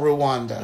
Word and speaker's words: Rwanda: But in Africa, there's a country called Rwanda. Mm-hmm. Rwanda, they call it, if you pronounce it right.